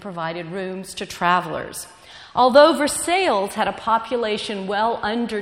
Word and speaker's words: Versailles: The town of Versailles had just been founded when Elizabeth and Henry came, and they do pronounce it Versailles, even though it's named after Versailles.